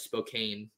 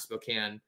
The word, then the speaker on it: Spokane: I know when I was at Gonzaga for the longest time, I thought it was Spokane, not Spokane. So, yeah, he did himself some favors there by making sure he pronounced everything correctly.